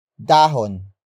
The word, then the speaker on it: dahon: Dahon. Dahon. Dahon is spelled as D-A-H-O-N.